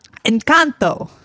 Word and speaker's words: Encanto: Encanto.